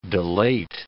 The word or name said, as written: delate